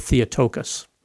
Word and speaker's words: Theotokos: Hence, the Church confesses that Mary is truly the mother of God, or the Theotokos, as it's pronounced in Greek.